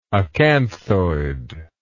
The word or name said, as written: acanthoid